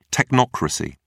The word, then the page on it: technocracy: British English